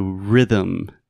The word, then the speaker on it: rhythm: So, rhythm. This is one of those words in English that the way we write it is absolutely crazy.